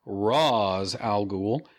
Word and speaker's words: Ra's al Ghul: And when Raish... Shit, I do this every time. With these movies, you have to pronounce it Ra's al Ghul, tells him that Gotham is impossible to save, he, meaning Bruce, only becomes more determined to achieve his vision.